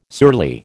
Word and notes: surly: American English

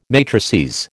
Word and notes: matrices: American English

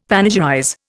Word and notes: panegyrize: Indian

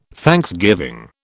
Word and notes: thanksgiving: British English

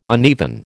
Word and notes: uneven: American English

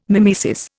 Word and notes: mimesis: Indian

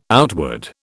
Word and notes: outward: British English

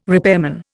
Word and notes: repairman: Indian

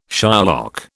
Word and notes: Shylock: British English

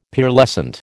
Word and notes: Pearlescent: American English

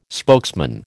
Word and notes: spokesman: American English

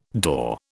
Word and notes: daw: British English

